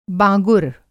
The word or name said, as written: बाँगुर